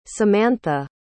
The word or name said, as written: samantha